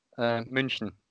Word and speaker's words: München: FC Bayern Munich. München, actually. Okay.